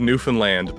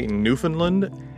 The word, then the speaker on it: Newfoundland: And nice pronunciation of Newfoundland, by the way. A lot of people say Newfoundland, and it's a bit of a sore spot with us locals.